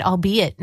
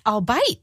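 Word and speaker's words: albeit: was hosting, and he had used it in a spot for one of the programs coming out and said, I'll be it. And I was like, wait, what? And I looked at the spot and I was like, no, that's I'll bite. Oh no, have I been saying it wrong in my head this whole time?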